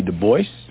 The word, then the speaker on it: Du Bois: Du Bois? – Yes. He always insisted on that pronunciation. He did not like the Gaelic pronunciation, though in his antecedents he was partly French.